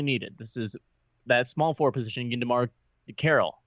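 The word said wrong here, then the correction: DeMarre: Did I say that right? DeMari Carroll. – Just say DeMari. – DeMari. There we go.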